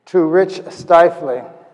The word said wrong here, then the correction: Shively: No, Shively, I'm sorry. I knew I'd mispronounced his name. It is Shively, right?